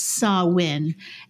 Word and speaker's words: Samhain: And the earliest was about 2,000 years ago, and it was Samhain, which is, it looks like it's Samhain, but it's pronounced Samhain.